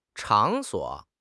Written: 场所

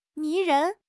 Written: 迷人

